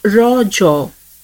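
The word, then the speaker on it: Raja: The festival is natively pronounced as Raw Jaw, a local term that means menstruating woman.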